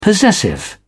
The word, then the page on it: possessive: British English